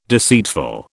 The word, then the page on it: deceitful: British English